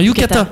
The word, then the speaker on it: yukata: Un yukita. – Non, c'est non, un yukata.